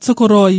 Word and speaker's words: Tokoroa: Do you know, we've talked about this off air before, but I have actually been to your hometown, Tokoroa. I'm probably saying it wrong. How do you say it? – It's perfect. You can say it how you want, but Tokoroa, you roll your R a little Tokoroa.